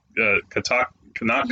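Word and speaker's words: Kanata: Like Maz Kanaka, who also I'm probably mispronouncing her name, Kanaka. – You're fine.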